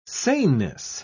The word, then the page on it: saneness: British English